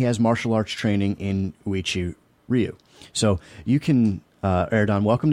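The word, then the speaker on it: Uechi-ryū: You uttered it just the right way. Uichi Ryu, it's called Uichi Ryu, and Zdenko Domanchik.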